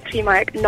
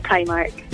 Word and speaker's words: Primark: It's definitely pronounced Primark, not Primark.